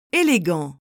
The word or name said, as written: élégant